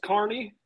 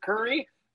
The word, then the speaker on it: Kearney: It is Kearney, uh, if you pronounce it Curry, they will actually hang you up by the trees and beat the shit out of you with metal rods.